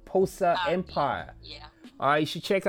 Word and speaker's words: Bosa Empire: Ladies and gentlemen, Pos Empire or Pos Empire. You should check her out and she sells one of the Posa Empire. – All right, so it is spelled You want to spell it or I should spell it? – B O S A E M P I R E.